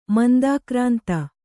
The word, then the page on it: ಮಂದಾಕ್ರಾಂತ: mandākrānta